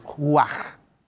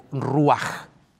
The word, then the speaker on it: ruach: Es que el término hebreo para traducir aliento en todo el Antiguo Testamento es una palabrita que se pronuncia de la siguiente manera en el hebreo, ruach o ruach.